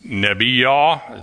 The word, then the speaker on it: Nebiyah: The Jewish word for prophetess is pronounced Nebiyah.